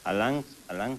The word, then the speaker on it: Alain: Alain Cruz, fíjate. – ¿Alain qué? – Alain. – Alain. – Cruz, de 18 años. – Alain. – Bueno. – Sí, se escribe Alain, pero se dice Alain.